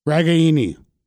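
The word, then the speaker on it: Ragaini: Heretofore pronounced Reganey by most of us, but somewhat in fun, he said the Italian pronunciation of his game is Reganey.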